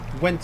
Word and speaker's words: Gwent: Gwent se llama. Así lo pronuncio yo, no sé si se pronunciará así. – Bueno, no sé. Sí. – Suena parecido. Para la gente que no nos está viendo, es G-W-E-N-T.